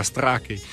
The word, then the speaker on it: Strachey: Romanzo che è stato respinto da André Gide, che non ne voleva sapere di pubblicare, lui amico della Strachey, e non ha voluto saperne. Scusa, perdona. – Strachey.